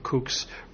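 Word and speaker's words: CUC: Well, there are stores that accept the kooks, which are, that's K-O-O-K-S, It's C-U-K-S. – I know, but the way it's pronounced. What is it really? – Cuban pesos. – Right, but it's pronounced kooks, which is K-O-O-K-S.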